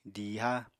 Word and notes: diiha: apatani

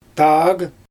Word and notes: taag: Xyzyl

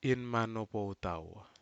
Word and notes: inmano potau: Aneityum

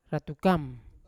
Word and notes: ratukam: Yawa

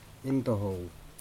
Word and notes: intoho: Aneityum

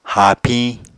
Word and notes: haapiñ: Apatani

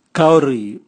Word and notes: kauri: Raga

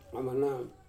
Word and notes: amana: Wamesa

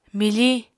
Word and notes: mifiñ: Apatani

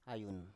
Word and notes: ayun: Biak

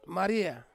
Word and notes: maria: Ansus

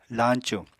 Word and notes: lañcu: apatani

